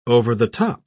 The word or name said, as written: over-the-top